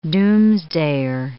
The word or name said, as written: doomsdayer